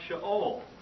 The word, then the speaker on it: Sheol: Which would be pronounced Sheol. And so Sheol is spelled S-H-E-O-L.